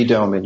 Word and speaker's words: Doman: And one of your most powerful songs is The Story of Howard Gray, originally written and sung by Lee Doman. Is that how you pronounce it, Doman? – That's correct, Lee Doman, yes.